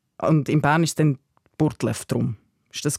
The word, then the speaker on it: Burgdorf: Das ist die etymologische Wurzel ist dann aber in der Aussprache, heute sagt man ja Burtlef, mhm.